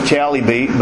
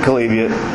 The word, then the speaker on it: Chalybeate: For the life of me, I figured people would pronounce this place Chalybeate, but they don't. They pronounce it Chalybeate, real close to the dictionary's directions.